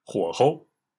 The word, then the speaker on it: 火候: 火是着火的火，候是时候的候，不过在这里要读作轻声，火候，火候。